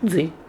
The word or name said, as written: Dzi